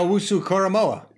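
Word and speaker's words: Owusu-Koramoah: Jeremiah Jeremiah Owusu Koramoah.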